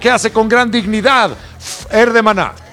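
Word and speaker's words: Fher: Fer. Así se pronuncia, Fer.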